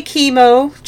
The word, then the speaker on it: Kimo: fun fact, I will go into it a bit more later, but actually, technically, it's supposed to be pronounced Himo. – Oh. – But nobody says it that way, because that's not what it looks like. – Yeah, because why would a K ever make that sound? – Yeah.